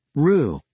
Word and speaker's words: roux: Roux, pronounced roux, is the thickening agent used in three of the five French mother sauces, espagnole, béchamel, and velouté.